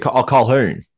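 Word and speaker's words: Colquhoun: Jesse Colhorn. How do you say his name? Colhorn? – It's Colhorn. – Colhern. Colhern. – Colhern. – Is it Colhoun? Okay, Colhoun, sorry.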